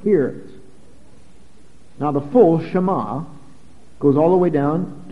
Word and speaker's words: Shema: The Jews called it the Shema, spelled S-H-E-M-A, pronounced Shema.